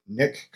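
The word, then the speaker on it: Nik: So for the next segment, the next item I want to talk about in this episode is the Nick Collection. That's how I pronounce it. I hope I'm pronouncing it correctly. It's N-I-K.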